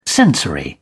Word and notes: sensory: British English

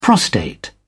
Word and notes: prostate: British English

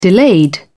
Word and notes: delayed: British English

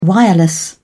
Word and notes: wireless: British English

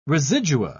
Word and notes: residua: British English

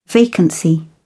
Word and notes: vacancy: British English

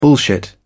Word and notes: bullshit: British English

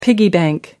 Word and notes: piggy bank: American English

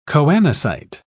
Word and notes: choanocyte: British English

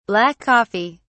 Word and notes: black coffee: British English

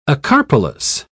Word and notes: acarpelous: British English

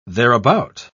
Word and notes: thereabout: British English

